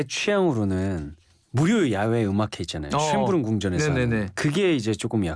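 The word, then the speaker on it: Schönbrunn: 신년 음악회가 비싼 티켓을 구입해 턱시도와 드레스를 입고 듣는 음악회라면 쉔부른 여름밤 음악회는 일반 대중을 대상으로 하는 무료 음악회입니다. – 전 쉰부터 갈수 있는 줄 알고. – 이게 발음이 어렵습니다. 쉰부른인데 쉰이 될 수도 있어요. 쉰부른.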